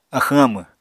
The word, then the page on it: axwñ-amw: Apatani